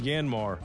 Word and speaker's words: Yanmar: Now, and you've wondered how to pronounce Yanmar mini excavators. You need way. It's Yanmar and ASAP working conditions.